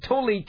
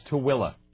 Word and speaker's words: Tooele: Apparently, Huntsman feels strongly that there'll be no low-level waste at the facility in Tolley County. – Oh, that's Tooele. – Tooele. It isn't spelled that way. – Yeah, no, it's spelled Tooele.